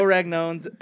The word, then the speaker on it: Ragnone: Joe Ragnone's Idioms for Idiots.